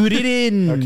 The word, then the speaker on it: Kuririn: Why are you saying it like that? – That's how they say it in Japanese. I think it's so goofy. – Is that how you say it? – Kuririn.